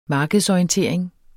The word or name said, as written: markedsorientering